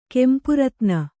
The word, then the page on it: ಕೆಂಪುರತ್ನ: Kannada